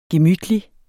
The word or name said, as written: gemytlig